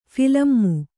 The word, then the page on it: ಫಿಲಮ್ಮು: philammu